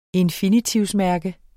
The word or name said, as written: infinitivmærke